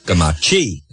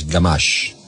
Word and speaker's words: Garmash: And the one I get is Gamachi. – Okay. – Because it's C-A-G. If you're not from Woonsocket, you can say Gamachi. – Gotcha. – No, it's Garmash. – Yes. – Silent E. Yes, indeed.